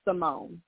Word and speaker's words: Samoné: Samoné? – Yes. – How do you pronounce it? Everyone wants to say it because that's how it's spelled. But, no, it's pronounced Simone. It's just Simone.